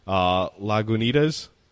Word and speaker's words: Lagunitas: And I believe it was, God, I think I'm going to butcher the pronouncing, Lagunitas? – Yeah.